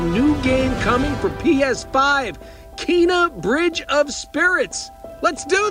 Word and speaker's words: Kena: I believe it's pronounced Kena.